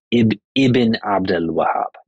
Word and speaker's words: Ibn Abdul Wahhab: And the proper way to say his name is Ibn Abdul Wahhab.